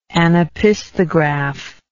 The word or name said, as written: anopisthograph